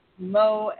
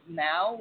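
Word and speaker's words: mow: And she does not know that you pronounce Mo as Mao when you're talking about part of the barn.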